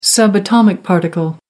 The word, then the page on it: subatomic particle: American English